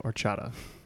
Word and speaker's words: horchata: I'm sure what you wrote is spelled correct, but I believe the pronunciation drops the H. We said horchata. – Yes, horchata is correct. – Horchata less correct.